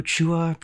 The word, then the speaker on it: Chua: But for the first of maybe about 26 years of my life, I thought that Chua was actually pronounced Chua. – Mm. – And I was like, Brian Doe Chua, Brian Chua.